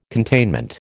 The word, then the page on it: containment: American English